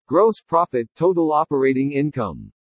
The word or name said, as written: GROSS PROFIT-TOTAL OPERATING INCOME